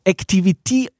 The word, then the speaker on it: Activity: Activity, so, das ist die richtige Betonung.